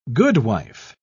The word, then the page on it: goodwife: British English